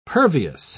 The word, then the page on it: pervious: British English